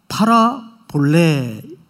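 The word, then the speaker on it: 파라 볼레 (παραβολή): 파라 볼레.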